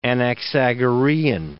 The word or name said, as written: Anaxagorean